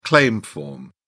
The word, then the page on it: claim form: British English